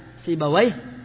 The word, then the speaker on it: سيبويه: مردويه عند أهل اللغة، أما عند المحدثين فيقولون مردوية ونفطوية وخالوية ومردوية ونفطوية وخالوية وحمدوية وراهوية وسيبوية وأمثالهم، وذلك لأنهم هكذا سمعوا أهلها ينطقونها فلا يتعدون السماع، أما أهل اللغة فإنهم يدخلون فيها القياس فيقولون سيبويه ونفطويه وخالويه وحمدويه وراهويه وهكذا، أما المحدثين فلا.